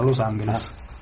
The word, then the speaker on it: Los Angeles: No, è la pronuncia spagnola, Los Angeles.